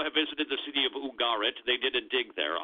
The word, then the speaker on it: Ugarit: This was from the ancient Canaanite city of Ugarit. Unless you're from the south, then it's probably Ugarit, because they tend to put the accent on the first syllable. I-N-S-U-R-A-N-C.